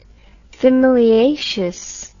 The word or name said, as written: thymelaeaceous